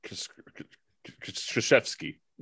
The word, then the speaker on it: Krzyzewski: Coach Krzyzewski.